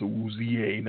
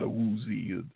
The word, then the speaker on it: Awuzie: Yeah, they finally got it straightened out. It's Awuzie, not Awuzie.